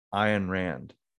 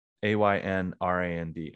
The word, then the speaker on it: Ayn Rand: like I'm I'm currently reading uh, Atlas Shrugged, uh, a book by a woman named Ayn Rand. I think that's how you pronounce it. A Y N R A N D, Ayn Rand.